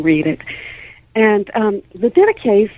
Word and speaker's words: Didache: It's called the Didache, and that's uh, that's spelled D I D A C H E.